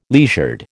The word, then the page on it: leisured: American English